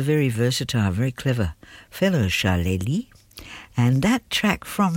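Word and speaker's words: Charlélie: Charlie, unusual name, C-H-A-R-L-E, accent on the E, L-I-E. Charlie, he's actually a French-American, but mainly French, electronic, multidisciplinary artist.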